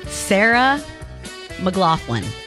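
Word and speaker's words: Sarah McLachlan: Sarah McLaughlin. Did I say it correctly? – I believe that's right.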